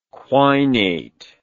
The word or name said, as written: quinate